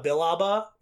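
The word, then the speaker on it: Billaba: Another character I've really started to admire, and basically should be my Star Wars mom, is Depa Balaba. I don't know. Is that how you pronounce her last name? – I've heard Depa Bilaba is how I've heard before.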